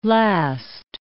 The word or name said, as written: last